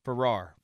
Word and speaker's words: Farrar: Like Ferrari without the last syllable. – That's it. That's right. – Farrar.